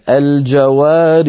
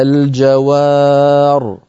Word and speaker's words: الجوار: نعم، أيضا ننبه هنا على أن الراء مفخمة وليست مرققة. المثال الثالث مثل المثال الثاني، الجوار الكنس في سورة التكوير، أصلها الجوار لكن حذفت الياء نطقا وخطا للتخلص من التقاء الساكنين فنقف عليها الجوار.